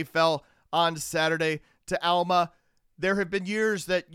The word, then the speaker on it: Alma: Yes, it's still pronounced Alma, folks.